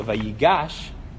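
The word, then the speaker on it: vayigash (ויגש): So vayigash, the emphasis is in the middle.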